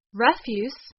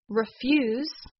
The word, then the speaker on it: refuse: As a noun, refuse means garbage that is thrown out regularly. The stress is on the first syllable of the word. So instead of refuse, a verb, we say it as refuse.